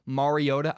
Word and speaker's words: Mariota: I want to clarify, I know that Marcus Mariota's name is pronounced Mariota.